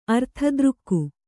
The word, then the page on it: ಅರ್ಥದೃಕ್ಕು: arthadřkku